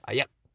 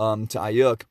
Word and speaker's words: Aiyuk: Is it Ayuk or Ayuk? I know it's spelled A-I-Y-U-K. Ayuk.